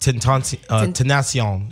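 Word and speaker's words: Tentación: Tentacion.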